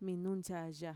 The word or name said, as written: minuntxaya